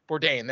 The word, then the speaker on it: Bourdain: But my next review, it comes from Anthony B., and I can only assume that is the late Anthony Bourdain? Bourdain. That's how his name is pronounced.